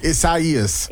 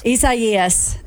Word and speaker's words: Isaias: But that hurricane ECS is, what is it? – How did you pronounce it properly earlier? – I know, and then I forgot. – Isaias. – Isaias.